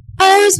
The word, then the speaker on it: Oguz: His name was Oz. You spell it O-G-U-Z. And this one friend of mine kept on calling him Uzi. Uzi this and Uzi that. But in any case, Oz was a very, very kind Muslim young man.